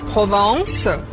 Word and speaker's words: Provence: A gente diz Provence, mas se escreve Provence.